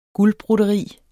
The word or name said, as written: guldbroderi